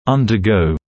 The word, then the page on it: undergo: ˌʌndə’gəu; ˌандэ’гоу